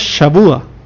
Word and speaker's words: Shavua: y perdona mi pronunciación, pero en hebreo es Shavua.